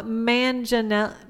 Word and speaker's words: Manganiello: It's Joe Manganiello.